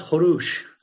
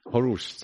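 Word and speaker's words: Kourosh: I think Cyrus is pronounced Horush. – Horush, something like that. – Yeah, I think that's how it would have been pronounced back then.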